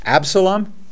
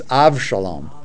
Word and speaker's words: Avshalom: Like Absalom, that's not how you say it in Hebrew. It's Avshalom.